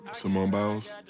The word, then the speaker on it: Simone Biles: Simone Biles. I'm saying her name right. Am I saying her name right? – Simone Biles. You sound good to me.